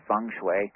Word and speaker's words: feng shui: The pronunciation looks, it's spelled looking like feng shui or feng shui, but it's actually pronounced feng shui, like the E with a U.